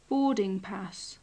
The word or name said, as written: boarding pass